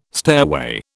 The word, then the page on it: stairway: British English